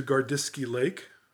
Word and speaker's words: Gardisky Lake: Paul, I wanted to talk to you today about your latest book, Getting to Gardiski Lake.